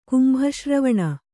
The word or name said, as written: ಕುಂಭಶ್ರವಣ